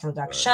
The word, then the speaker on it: production: And the stress, where can we put the stress? – Oh, that's the one I mentioned earlier. Yeah, it's not production. It's not production. Right. It's production. So it's in the second syllable.